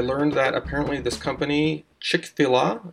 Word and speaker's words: Chick-fil-A: No, I believe Chick-fil-A is how we say it here in the United States, but I'm not for sure how they pronounce it in Canada. – I've already alienated most of my listeners. So Chick-fil-A, F-I-L-A,